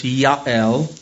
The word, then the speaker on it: Jael: But Sisera fled away on foot to the tent of Jael, or technically in the Hebrew, it's Yael, because there's no hard J sounds in Hebrew. He fled away on foot to the tent of Jael,